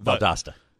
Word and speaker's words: Valdosta: I may have butchered that. – Valdosta. – Thank you.